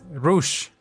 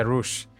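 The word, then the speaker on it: Rouge: Tiene como una parte 2 que se llama Innocent Rogue. Eh, no es Rogue, es Rush. Se pronuncia Rush.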